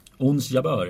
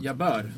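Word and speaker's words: Ons Jabeur: Och ett litet genombrott också för, eh, jag är så dålig på att uttala det, Uns Jabir. Ons Jabör, ja. Jabör.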